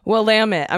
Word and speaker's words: Willamette: Also, we had several listeners write in to correct the pronunciation of Willamette. Our editor, Desiree, would like to be on the record as knowing the correct pronunciation, knew the right way, but was helpless to fix it. I'm sorry, Des. Willamette. I'm going to remember.